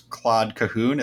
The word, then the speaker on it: Claude Cahun: is Claude Cahun.